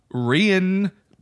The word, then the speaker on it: Rian: He actually did a surprise visit to South by Southwest this year to talk to Rian. I'm not saying Ryan. It's spelled R-I-A-N. It's Rian. That's Rian. Rian Johnson.